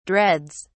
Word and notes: dreads: British English

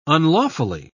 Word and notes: unlawfully: British English

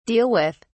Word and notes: deal with: British English

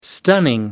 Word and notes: stunning: American English

